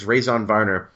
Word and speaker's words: Raison Varner: It looks like Raison Varner, and I feel bad because it sounds like Raisin, but I'm almost positive it's Raison Varner.